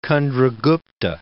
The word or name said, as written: Candragupta